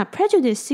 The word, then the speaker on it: prejudice: 那 prejudice 这个字的发音有一个比较需要注意的地方，是它的开头 PRE 的部分， E 是发短音的 E。